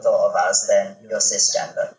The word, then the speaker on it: cisgender: Because the other one that you mentioned is how do you say it? Cisgender. – Yeah, that's right.